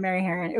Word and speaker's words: Harron: So it was kind of, Mary Harron, it was kind of like that for like a hot minute, but I'm glad I got her name right.